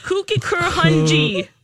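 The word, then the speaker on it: Kooikerhondje: K-O-O. – K-O-O. – I-K-E-R. – I-K-E-R. – H-O-N. – H-O-N. – D-J-E. How would you say that? Yeah. Kuikenhonden? Hunjay? Kuikenhonji. – Kuikenhonji.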